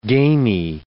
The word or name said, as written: gamey